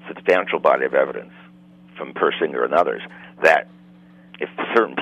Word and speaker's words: Persinger: Isn't there, and help me if I mispronounce the name, isn't there a doctor, I think it's Michael Persinger? – Persinger, right.